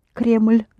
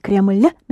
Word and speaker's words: Кремль: The word for Kremlin, that's the English version of the word Кремль. Кремль, there's no лин. So how do you say, I want to go to the Kremlin? – Я хочу пойти в Кремль. – Да, Кремль. Make it soft. There is a soft sign in the end.